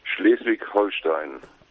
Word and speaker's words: Schleswig-Holstein: Schleswig-Holstein.